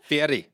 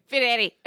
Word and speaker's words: Fieri: Oh, you said it wrong. – Celebrity cook-off. Fieri. – Fieri.